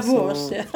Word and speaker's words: Davos: Davos tror jag det uttalas. Jaha, Davos. Ursäkta.